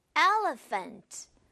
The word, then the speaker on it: elephant: 여러분, 요거 발음하실 때 PH 요 부분을 발음을 F 이렇게 해주는 거예요. 프흐가 아니라 이렇게 합니다. 그래서 elephant, elephant라고 발음하는 거예요.